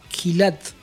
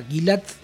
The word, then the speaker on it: Gilad: A ver, no sé cómo será originalmente, es G-I-L-A-D, Gilad, o sea, Gilad, sí.